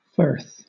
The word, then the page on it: firth: British English